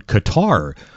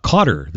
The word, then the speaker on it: Qatar: Next up on the sporting calendar today, Canada's second game at the World Cup, taking on Qatar. Or Cotter this afternoon, depending upon how you want to pronounce it.